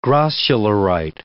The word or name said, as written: grossularite